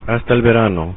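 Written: hasta el verano

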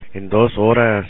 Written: en 2 horas

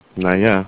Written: naja

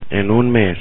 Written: en un mes

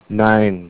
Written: Nein

